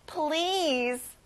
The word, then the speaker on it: please: 정중하게 부탁할 때 덧붙이는 말, 제발, please. 자, 여러분, 긴 단어고요. 끝에 z 발음을 해보세요. Please.